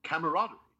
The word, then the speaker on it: camaraderie: I don't, how do you say it? – Camaraderie. – Camaraderie, yeah. Camaraderie. – Right.